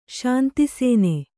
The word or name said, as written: ಶಾಂತಿಸೇನೆ